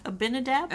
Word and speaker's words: Abinadab: David saw how God blessed Abinab. How do you say that? – Abinadab? – Abinadab.